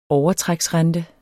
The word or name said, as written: overtræksrente